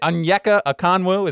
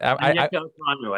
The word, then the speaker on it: Onyeka Okongwu: Is it Onyeka Okongwu? Is that Onyeka Okongwu? I think that's how you say it.